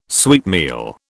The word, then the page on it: sweetmeal: British English